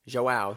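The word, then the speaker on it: João: Moving on to the next match, we had John Battle, which I think is a badass last name, versus Yao Tavares. – Yao? – Joao, Josh. Yeah. Joao. I looked at his name and then I said it wrong, as per usual.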